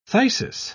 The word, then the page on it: phthisis: British English